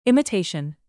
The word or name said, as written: imitation